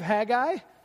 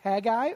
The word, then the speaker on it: Haggai: By the way, the pronunciation of Haggai, you can go either way. You can pronounce it Haggai or Haggai or Haggai. You'll hear me say all three at some time, and it's okay. You know, you can pronounce Haggai any way you want, except for Frank. It doesn't sound anything like Frank, but you can say it any way you want.